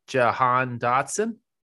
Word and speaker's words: Jahan Dotson: I could go, I can't say it right, but I'm going to say it anyway, Jahan Dotson. – You got it.